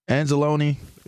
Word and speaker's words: Anzalone: Cause I I, I coached uh Anzalone. Am I saying it right, Brady? Anzalone yeah, yeah. Alex Anzalone.